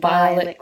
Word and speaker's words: violet: Where ox slips and the nodding violet grows. In fact, yeah, the word violet, you could say that with three syllables. – Violet. – But it's often done with just two.